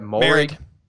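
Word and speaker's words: Moehrig: I really like Morig out of, am I saying it right? Merrig.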